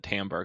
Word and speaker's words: Tambor: See, I'm just still like digesting that everyone calls it Tambor, because I definitely have been saying that wrong in my head. – Oh no, I say Timber.